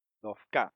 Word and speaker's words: Dofka: Porque todo el mundo tiende a decir Dofka, ¿verdad? Pero él pone el acento en la A, aunque tiene una H al final, no importa, porque eso no es una palabra española.